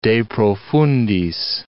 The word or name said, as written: de profundis